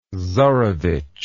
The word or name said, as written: czarevitch